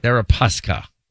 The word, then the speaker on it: Deripaska: Deripaska.